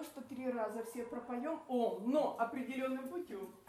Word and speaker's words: Ом: И вот это Ом, оно состоит из трех букв А, У, М, да? Но ну, не то, что А, а такое более смягченное, это что-то А между О, да?